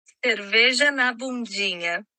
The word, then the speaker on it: cerveja na bundinha: les voy a poner aquí mismo cómo se pronuncia correctamente en portugués. Déjame allá. Ok, so en portugués se pronuncia Cerveja na bundinha.